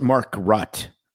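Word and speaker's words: Makrut: Market Lime. – Mark, but it's M-A-K-R-U-T. – M-A-R-K-U-T. Market Lime. – And it's Mark Rutt Lime.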